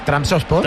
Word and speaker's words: Trabzonspor: Transportes. – Nunca he sabido decir este equipo. – No, aquí sois todos que pronunciáis de academia. – Pero ¿cómo se dice? ¿cómo es? – Es Transosport.